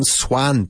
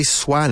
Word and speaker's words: swan: Yeah, S-W-A-N. A swan. Un cisne. Claro. But be careful with the pronunciation. Okay, it's written swan, but we don't say swan. It's swan. It's an O sound, right?